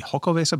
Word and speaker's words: Xocoveza: It's Xocoveza. So the Xocoveza? X, apparently, I looked it up and a couple of different people have said this. The X in X-O-C-O is pronounced with an S-H sound.